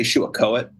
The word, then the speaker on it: Shuakoet: So I trust Tad on this, and I was pleased that we'd come to the same conclusion, but there are various other ways of pronouncing it that sound a little more like Saco, but Shuakoet, I guess kind of in line with Pentagoet and other early place names,